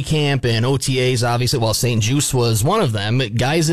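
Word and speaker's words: St. Juste: I just found out, it's Benjamin St. Juice. The T is silent.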